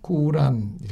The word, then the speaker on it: 꾸란: 마호메트가 죽은 후에 그가 제정한 규칙들을 이렇게 모아서 책으로 만드는데 이걸 코란이라고 그러죠. 뭐 다른 발음으로 뭐 꾸란 이렇게 발음을 하죠.